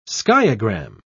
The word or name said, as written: skiagram